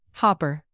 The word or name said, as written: hopper